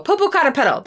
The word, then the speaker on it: Popocatépetl: Oh, Popocatépetl.